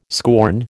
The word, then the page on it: scorn: American English